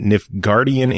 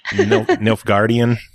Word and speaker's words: Nilfgaardian: Pronounced Nifgard again. That was funny. – Nilfgaardian? – Yeah, there you go. I don't know if it's any better. I can't pronounce it either.